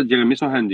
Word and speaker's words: Sochan: Sohan ee, mi okunuyor? Sohan mı okunuyor ismi? – Valla Amerikalılar Jeremy Sohan diyor. – Tamam ben Sochan diyeceğim o zaman.